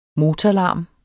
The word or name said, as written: motorlarm